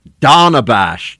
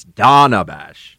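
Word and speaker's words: Dana Bash: So here you have CNN with Donabash. It's not Dana, even though it's spelled Dana. It's Donabash.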